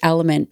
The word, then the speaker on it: LMNT: It sounds like Element, but it's spelled L-M-N-T.